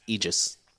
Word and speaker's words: Aegis: So, real quick, before you answer this one, I recently found out it's pronounced Aegis. – Okay, cool. Well, that's Aegis then is how we're going to do it.